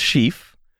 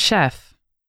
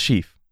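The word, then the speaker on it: Shef: We're going to start with Sheaf. It's S-H-E-F. – Is that not chef? – No, it's Sheaf.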